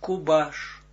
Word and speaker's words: Kubash: Dus als Gvora zijnde, zie je daar een tegenligger van hem is Kubash, wordt uitgesproken als Kubash.